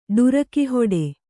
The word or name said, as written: ಡುರಕಿಹೊಡೆ